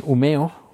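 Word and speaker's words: Umeå: En Umeo, que se escribe Humea con una, un puntito arriba, que, que tuve una producción en el hospital y fue un total desastre.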